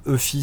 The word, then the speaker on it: Eufy: Donc euh, ben, c'est une des applications aussi que j'utilise assez souvent, c'est euh, donc euh, l'application EFI Security. donc mes, mes, caméras sont des caméras EFI, U, ça s'écrit E-U-F-Y.